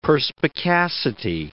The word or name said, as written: perspicacity